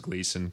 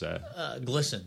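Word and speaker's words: Glisan: So here in Portland, just for anyone who's not from Portland, we got a street that is commonly referred to as Gleason because it's G-L-I-S-A-N. But apparently the proper pronunciation, is... – Glisson.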